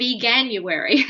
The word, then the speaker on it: Veganuary: I think it's called Veganuary. – Yeah, maybe. Maybe that's how you pronounce it.